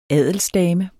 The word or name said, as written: adelsdame